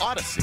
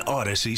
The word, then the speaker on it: Audacy: Spelled like it sounds. A-U-D-A-C-Y. – Yep. – Odyssey. – 92.9 FM ESPN. An Odyssey station.